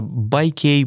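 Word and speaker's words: Buyk: которая называется Байкей, Буйк, Бьюк. Поправьте меня, как она называется, я, честно говоря, как правильно. Читается Байк. Примерно как и велосипед, Байк. да, только пишется иначе.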